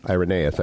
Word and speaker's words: Irenaeus: Irenaeus, I believe that's how you say his name. – That's correct.